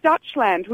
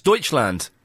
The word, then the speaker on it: Deutschland: And you actually said Dutchland, which is Germany, not the Netherlands. – No, that's Deutschland. – Yeah, but it's spelt like D-E-U or however it is.